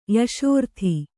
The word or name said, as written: ಯಶೋರ್ಥಿ